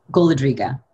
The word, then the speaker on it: Golodriga: Golodriga.